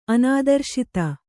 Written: ಅನಾದರ್ಶಿತ

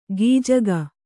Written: ಗೀಜಗ

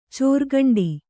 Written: ಚೋರ್ಗಂಡಿ

